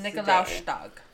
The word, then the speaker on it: Nikolaustag: And then the day is called Nikolaustag. – Which is St. Nicholas. – Nikolaustag.